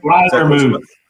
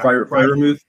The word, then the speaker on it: Freiermuth: Freiermuth. – Yeah. – Freiermuth.